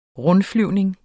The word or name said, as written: rundflyvning